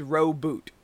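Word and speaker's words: Roboute: Everyone I know says Gilliman. Mm-hmm. They make the U is silent, no, the I is silent. It's Gulliman, and it's Roboot.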